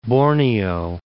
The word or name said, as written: Borneo